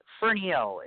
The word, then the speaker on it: Fernihough: Carrie Fernihough is how it's pronounced.